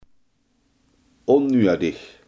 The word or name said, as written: onnodig